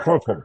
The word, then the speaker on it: Hartel: And I think Hartel's name would be pronounced Hartel. I don't think of like Hartel, like an E. I think Hartel. Correct. – Hartel would be the proper pronunciation.